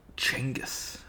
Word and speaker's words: Chinggis: Hey, Genghis? – Chinggis, I believe it's pronounced.